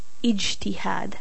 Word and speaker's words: ijtihad: When I decided to study Islam on my own, after being kicked out of the madrasa, I learned about a word pronounced as ijtihad. I-J-T-I-H-A-D, ijtihad. And yes, you're right. It sounds frighteningly like jihad to many non-Arab ears.